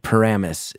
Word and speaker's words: Paramus: Paramus is the way to pronounce it.